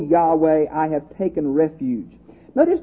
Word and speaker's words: Yahweh: YHWH, we think, is pronounced Yahweh.